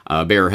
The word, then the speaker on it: Bayer: It's technically pronounced Bayer, but I'm Canadian, so I'll say Bayer.